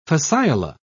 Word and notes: fasciola: British English

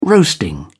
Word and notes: roasting: British English

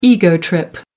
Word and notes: ego trip: American English

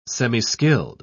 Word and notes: semiskilled: British English

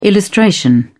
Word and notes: illustration: British English

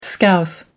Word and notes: scouse: American English

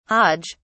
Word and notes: hodge: British English

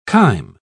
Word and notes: chyme: British English

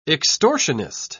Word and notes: extortionist: British English

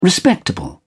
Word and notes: respectable: British English

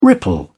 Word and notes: ripple: British English

